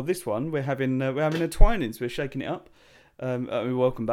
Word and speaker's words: Twinings: Twinings. Yeah, Matt doesn't know how to pronounce Twinings.